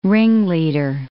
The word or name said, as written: ringleader